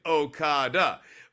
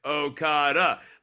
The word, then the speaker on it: Okada: it's like, Okada, Okada, like even the back punch, having the emphasis on the last syllable there.